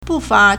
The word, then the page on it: 不乏: bùfá